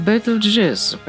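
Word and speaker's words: Betelgeuse: В английском Бетельгейзе звучит как «Бетельджез», почти как «Битлджуз», персонаж фильма ужасов Тима Бертона. «Битлджуз» дословно переводится как «жучиный сок».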